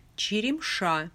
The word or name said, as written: Cheremsha